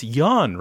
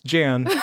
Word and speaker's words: Jan: Out of ten, I always get, oh, it's pronounced Yan, right? I'm like, no, it's Jan.